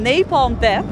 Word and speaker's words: Napalm Death: Op het rockpodium stond vanavond Napalm Death.